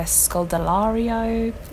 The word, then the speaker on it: Scodelario: You've got Zac Efron, Lily Collins, Akaya Scholar. I never pronounce her name right. Akaya Scodelario.